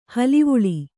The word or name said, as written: ಹಳವಳ